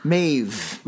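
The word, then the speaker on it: Mebh: Maeve. Yeah. I think it's spelled differently, but they pronounce it Maeve.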